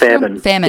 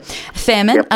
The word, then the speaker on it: Faehrmann: Now, it's being officially announced at 10:30 this morning by the Upper House MP, Cate Faehrmann. Is that how I say it right? – Faehrmann. – Yep. Faehrmann. Yep.